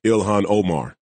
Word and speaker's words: Ilhan Omar: Ilhan Omar.